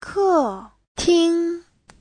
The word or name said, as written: ke4ting1